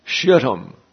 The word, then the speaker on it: Shittim: Transjordan, Shittim. That is the correct pronunciation, and I'm reluctant to use it, but there you go.